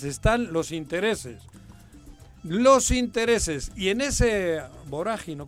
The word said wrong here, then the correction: vorágine: ¿cómo le llaman? – En esa vorágine. En esa vorágine. Vorágine.